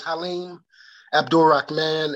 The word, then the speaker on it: Haleem Abdul Rahman: Yes, my name is Haleem Abdul Rahman, and I am the creator of the course, Men's Health, A Journey Towards Sensual Healing.